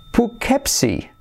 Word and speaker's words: Poughkeepsie: Se pronuncia Poughkeepsie.